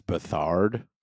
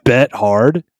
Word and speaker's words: Beathard: I think that's bet hard, dude. – No, it's beat hard, actually. CJ beats hard.